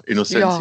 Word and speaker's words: Innocentia: Ja, also äh, Innocentia Park ist, äh, spricht man den eigentlich so aus? Was habe ich gesagt? – Ich glaube ja, Innocentia.